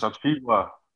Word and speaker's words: Achiuwa: So Precious Achiuwa.